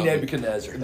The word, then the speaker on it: Nebuchadnezzar: I said Team it's, it's King Nebuchadnezzar. – There you it. go, Team Nebuchadnezzar.